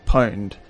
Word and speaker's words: Pwned: One of the best sites we found is called Have I Been Pwned? – And how do you spell that? – It's P-W-N-E-D. – Okay. – So okay. – It's like owned with a P.